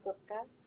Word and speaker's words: Gupta: It's Aditi Gupta.